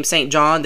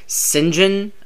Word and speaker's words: St. John: I know, like, in England, it's pretty common to have the name Saint John, though they pronounce it mysteriously St. John.